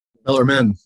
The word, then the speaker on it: Bellarmine: Is it Bellarmine? – Bellarmine. Yeah. Bellarmine.